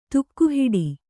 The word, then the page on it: ತಗಹಿಡು: tagahiḍu; Kannada